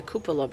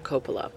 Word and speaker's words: cupola: The landmark architectural feature was renovated for the Grand Hotel Centennial and opened in 1987 as the Cupola Bar. I always pronounced it wrong. It's cupola, not copola.